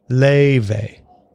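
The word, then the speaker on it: Laufey: Leve. – Leve.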